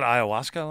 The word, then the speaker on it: ayahuasca: was it ayahuasca last season? – Yes, that's correct. Right. I didn't know how to pronounce it, so thank you for doing that.